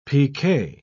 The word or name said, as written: pk